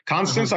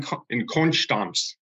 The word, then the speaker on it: Konstanz: Constance, uh-huh. I was in Munich, I was in Berlin, I was in Heidelberg, I was in Mannheim, but mostly in Konstanz is how they say it.